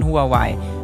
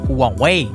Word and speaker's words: Huawei: Viele fragen sich nämlich, ist das vielleicht gefährlich und was steckt eigentlich hinter diesem riesigen Konzern Huawei oder Huawei, wie er eigentlich ausgesprochen wird?